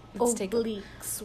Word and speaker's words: obliques: Obliques workout.